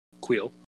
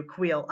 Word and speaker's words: Kuiil: Nick Nolte as Kuiil. – Um, thank you, Kuiil. I I can never. It doesn't look the way it's spelled, and I'm like, well, how do I do this? But yes, Kuiil.